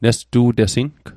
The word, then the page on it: nestu'tesing: Mi'gmaq